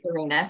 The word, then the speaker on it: Cyrina: So it's Cyrina, C-Y-R-I-N-A Talbott.